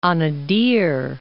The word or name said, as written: Anadyr